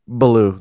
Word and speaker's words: Ballew: I mean, you know, if we're just going to go on the age thing here, Christopher, do you pronounce his last name Ballew? – Ballew, that's correct.